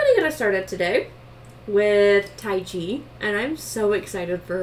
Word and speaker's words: Tyche: So Tai Chi which is spelled T Y C H E, is also written Tai Tai Chi as in T Y K H E, but it's pronounced Tai Chi, like the martial arts.